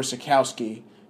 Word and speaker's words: Sitkowski: So what you're going to hear now first you're going to hear my interview with 2018 four-star quarterback Artur Sitkowski.